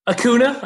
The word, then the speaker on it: Acuña: Acuña. I think there's some maybe alternate pronunciations, but for me, it's Acuña. Might be a tilde over that N.